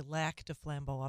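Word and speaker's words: Lac du Flambeau: I'm a student of French, and I know they say Lac de Flambeau up there, but Lac is the proper French pronunciation. But I guess since we're in the United States, I'll say Lac de Flambeau.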